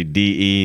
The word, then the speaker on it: De: It's spelled D-E, so it's De, but everybody says D, so I guess we just say D.